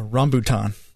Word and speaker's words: rambutan: That's Rambutan.